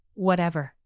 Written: whatever

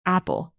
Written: Apple

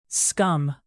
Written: scum

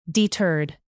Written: deterred

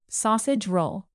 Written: sausage roll

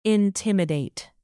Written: intimidate

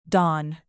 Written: dawn

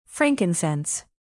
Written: frankincense

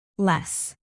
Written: less

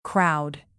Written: crowd

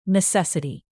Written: necessity

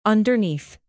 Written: underneath

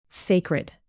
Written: sacred

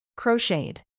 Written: crocheted

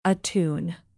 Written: attune

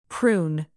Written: prune

